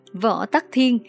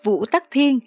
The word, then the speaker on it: Võ Tắc Thiên: Võ Tắc Thiên, sinh ngày 17 tháng 2 năm 624, mất ngày 16 tháng 12 năm 705, cũng được đọc là Vũ Tắc Thiên,